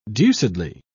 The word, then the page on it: deucedly: British English